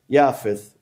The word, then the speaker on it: Japheth: It's written Japheth, but the way you pronounce it is Yafeth, which is the son of Nuh.